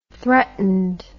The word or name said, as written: threatened